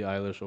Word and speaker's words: Eilish: But the big winner of the night was Billie Eilish.